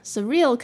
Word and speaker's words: surreal: Surreal 可以发音简念 S-U-R, sur, sur, 然后加上 real. Surreal, surreal.